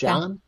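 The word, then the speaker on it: Jann: It's kind of looks like John. It's J-A-N-N. – Oh, okay. – John.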